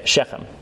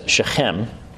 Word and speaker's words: Shechem: Okay, Shechem. Technically, in Hebrew, you're supposed to pronounce that as Shechem.